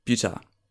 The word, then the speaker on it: Butoh: So, Butoh, spelled B-U-T-O-H. – I've definitely been thinking that as Butoh for a long time. – I've never pronounced it correctly in my life before today, so don't worry.